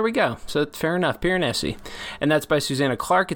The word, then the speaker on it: Piranesi: Did we ever settle on a pronunciation? Piranesi? – Piranesi. – Yeah, for no reason in particular, a very Italian sound, a little Italian twang to that.